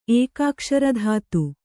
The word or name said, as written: ಏಕಾಕ್ಷರಧಾತು